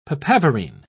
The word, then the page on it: papaverine: British English